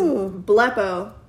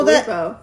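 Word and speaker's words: blepo (βλέπω): The way you pronounce it is blepo. – No, blepo.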